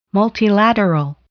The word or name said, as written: multilateral